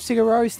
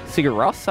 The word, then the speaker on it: Sigur Rós: I purposely pronounced it more like Rose and not how I'd usually pronounce it. – Which would be what? – Sigur Rós, I'd usually say.